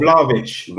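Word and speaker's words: Vlahović: So somebody like the Florentina striker, I don't know how to pronounce his name, call him V-Dub. and Gabby. – Vlahovic.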